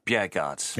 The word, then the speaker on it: Bjerregaard: I'm going to go for Lucas Bjerregaard.